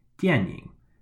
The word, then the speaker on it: 电影: And then I said the word that we went over before, 电影, which means film or movie. And that's spelled D-I-A-N in the fourth tone and Y-I-N-G in the third tone. 电影.